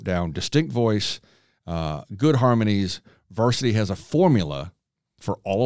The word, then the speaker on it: VRSTY: It's spelled VRSTY, Varsity.